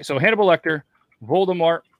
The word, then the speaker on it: Voldemort: Did I say that right? Voldemort? – Yeah.